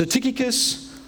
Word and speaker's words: Tychicus: Tychicus. Is that the correct pronunciation? According to Google it is, but I mean it could quite easily be Tai Chi Cuss. We'll go with Tychicus. So Tychicus,